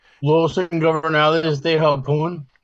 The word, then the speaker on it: Los Ingobernables de Japón: Los Ingobernables de Japón? – No. Oh, maybe. I don't know. Maybe Los, you're right. – Los Ingobernables.